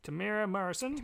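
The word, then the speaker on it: Temuera Morrison: Tamura Morrison? – Tamura Morrison.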